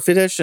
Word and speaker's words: Fidesz: It was not terribly surprising that Viktor Orban's party, his Fidesz party, I'm not sure if I'm pronouncing it, I'm probably butchering that, I'm sorry. – I pronounce it Fidesz.